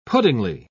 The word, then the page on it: puddingy: British English